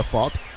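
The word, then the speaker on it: Pfaadt: El derecho, Brandon Fott. Se deletrea P-F-A-A-D-T. Y se pronuncia Fott.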